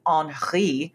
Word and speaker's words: Henri: You mentioned Haiti, but I mean, we're looking at, Hurricane Henri. That's how they pronounced it on NPR today.